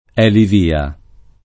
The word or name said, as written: elivia